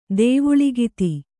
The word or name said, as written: ದೇವುಳಿಗಿತಿ